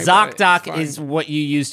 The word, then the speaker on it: ZocDoc: This actually says ZocDoc, which rhymes with Shock Doc.